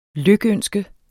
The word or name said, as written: lykønske